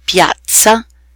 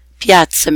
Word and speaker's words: piazza: Double Z sounds just like a single Z, but held slightly longer. The words pizza, pazzo, piazza, and Abruzzo. Pizza, crazy, piazza meaning square or plaza, and the region Abruzzo.